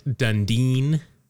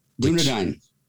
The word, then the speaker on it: Dunedin: And I named a city in Florida and I called it Dundeen. – Dunedin.